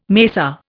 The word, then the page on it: macer: Indian